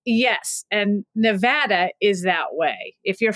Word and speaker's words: Nevada: How do you pronounce our state's name? – It's Nevada.